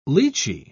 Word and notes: lichee: British English

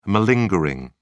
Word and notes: malingering: British English

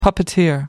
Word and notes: puppeteer: American English

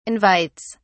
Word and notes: invites: British English

